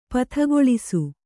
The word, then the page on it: ಪದಗೊಳಿಸು: padagoḷisu